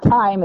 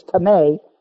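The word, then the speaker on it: timē: The word for honor is the word tame. That's a long E. It's not time, it's tame. And it means an inestimable value.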